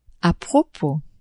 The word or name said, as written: a propos